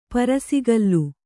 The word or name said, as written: ಪರುಸಗಲ್ಲು